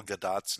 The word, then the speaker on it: Gadot: I do have to be my quibbling self with your pronunciation of Gal Gadot's name. Because she's not French. She's Israeli. And it's actually pronounced Gadot. So I've heard her say her own name.